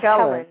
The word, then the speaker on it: Kellen: This is Kaylin. I'm saying that correctly. Gillespie? – No, it's actually Kellen. Like Kellen. – Kellen. Yes.